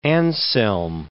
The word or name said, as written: Anselm